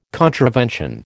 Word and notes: contravention: American English

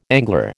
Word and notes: angler: American English

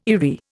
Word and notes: eyrie: Indian

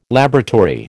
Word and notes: laboratory: American English

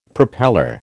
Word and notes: propeller: American English

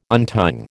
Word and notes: untying: American English

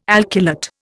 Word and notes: alkylate: Indian

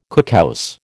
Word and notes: cookhouse: American English

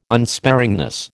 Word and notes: unsparingness: American English

